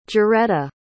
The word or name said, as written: joretta